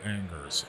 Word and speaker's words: Angers: We would say it Angers. It's spelled Angers. – Angers. – A-N-G-E-R-S.